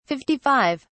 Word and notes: 55: British English

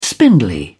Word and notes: spindly: British English

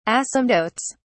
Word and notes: asymptotes: British English

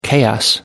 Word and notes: chaos: American English

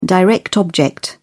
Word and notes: direct object: British English

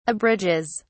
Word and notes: abridges: British English

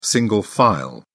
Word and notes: single file: British English

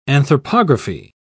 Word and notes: anthropography: British English

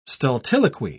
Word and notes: stultiloquy: British English

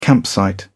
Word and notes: campsite: British English

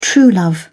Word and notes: true love: British English

